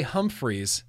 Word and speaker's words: Humphreys: L. Ray Humphreys, rhymes with grumpies, you know.